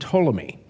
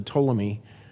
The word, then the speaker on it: Ptolemy: One is named Ptolemy, and it's spelled Ptolemy. Like pneumonia is spelled pneumonia.